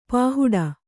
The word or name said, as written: ಪಹಡು